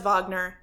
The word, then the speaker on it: Wagner: So Wagner, spelled Wagner, pronounced Wagner, he was German.